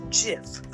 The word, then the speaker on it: GIF: It's pronounced GIF, like the peanut butter.